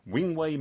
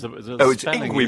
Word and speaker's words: Yngwie: Wingway Malmsteen. – It's Yngwie Malmsteen.